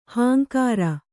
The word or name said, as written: ಹಿಂಗಾರ